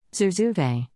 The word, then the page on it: zurzuvae: American English